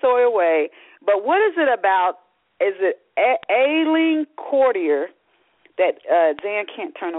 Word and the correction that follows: Aisling: Did I say the name right? – Yes, it's Aisling. – Aisling, okay.